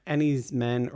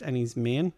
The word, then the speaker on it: Enys Men: And then my number one, Enys Men, or I think it's actually pronounced Ennis Main.